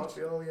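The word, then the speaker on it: Gvardiol: And it was funny because Alan Shearer, when he was talking about the Croatian defender, Gardiol, pronounced that right? – Gardiol, yeah.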